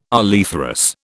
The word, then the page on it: oleiferous: British English